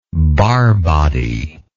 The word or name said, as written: barr body